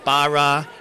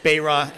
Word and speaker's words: Baraw: Is it Barra? Is it Barreau? Is it a whole slew of different types? And Bayraw, I appreciate you pronouncing it correctly and starting the trend of 2023 on the right note.